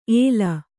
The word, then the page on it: ಏಲ: Kannada